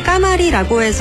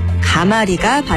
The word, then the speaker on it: 가마리: 발음이 까마리라고 해서 까마리로 표기하면 틀리는데요. 가마리가 발음 표기입니다.